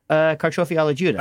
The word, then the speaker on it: Carciofi alla Giuda: How do you say it? – Carciofi alla Giuda. – Alla Giuda, that's right.